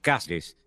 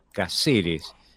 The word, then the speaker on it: Cáceres: con acentos en la primera sílaba, en el, en el caso de Cáceres, ¿no? Por eso Cáceres se convertía en Cáceres, porque el quichua es una lengua eminentemente grave. Entonces, no hay cáceres, hay caceres.